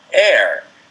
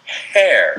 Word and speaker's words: heir: If you have inherited something or will inherit something, you are an heir. Now, this is spelled H. E-I-R. – And common sense would just indicate that you'd want to say hair. – Right. – Which would be a different word.